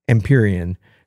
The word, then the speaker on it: Empyrean: And part of that is because now not only am I feeling confident because I know how to say it, Empyrean.